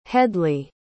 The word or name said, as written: hedley